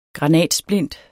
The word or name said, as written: granatsplint